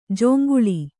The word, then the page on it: ಜೊಂಗುಳಿ: Kannada